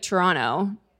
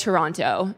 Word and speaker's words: Toronto: And I also know that I think they like when you say it like Toronto. Not like Toronto. It's like Toronto.